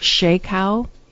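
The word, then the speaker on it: Charcot: It is spelled C-H-R-C-O-T. And I believe it's pronounced Shakow.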